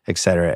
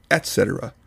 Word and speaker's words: et cetera: At 2:09, Bill has the fatal flaw of mispronouncing a famous Latin phrase. – et cetera, et cetera. – It is, of course, correctly pronounced et cetera. – Et cetera. – You see, the word is spelled E-T space C-E-T-E-R-A.